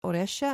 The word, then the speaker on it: Auresia: This is Oresha, and it's spelled differently, A-U-R-E-S-I-A, rather than O-R-Y-C-I-A, the traditional spelling.